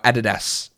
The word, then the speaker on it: Adidas: And I know you Americans call it Adidas, but Adidas is a German company. It's a German word, and it's pronounced in German Adidas. It's not pronounced Adidas.